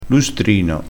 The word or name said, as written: lustrino